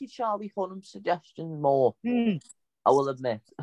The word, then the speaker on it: Charlie Hunnam: Oh yeah, Charlie Hunnam.